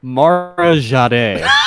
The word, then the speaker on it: Mara Jade: I always want to pronounce Mara Jade as Mara Jade for some reason, and it drives Mary nuts. – I believe it's Mara Jade.